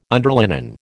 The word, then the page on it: underlinen: American English